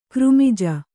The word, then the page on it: ಕರ್ಮಜ: karmaja